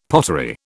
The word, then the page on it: pottery: British English